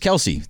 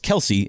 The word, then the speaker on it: Kelce: So, you know, Travis Kelce, the tight end for the Kansas City Chiefs, he came out a couple days ago and said that it's not Travis Kelce, it's Travis Kelce.